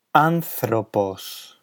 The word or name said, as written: anthropos